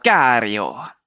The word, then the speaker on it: Sicario: als Regisseur von Subua und Cesario 2 oder Sicario, wie immer man es aussprechen mag. Sicario. Sicario, ja.